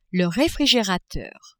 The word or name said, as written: refrigerateur